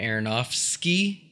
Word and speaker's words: Aronofsky: And it's directed by Darren Aronofsky.